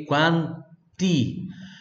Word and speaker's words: quanti: Quanti, pronuncia pure do T, ok? Quanti.